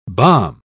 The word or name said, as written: bomb